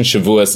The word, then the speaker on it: Shavuos: Well, like, so it's Shavuot is one pronunciation. Shavuos is like the more European pronunciation.